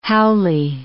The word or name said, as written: haole